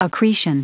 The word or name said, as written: accretion